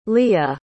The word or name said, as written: liya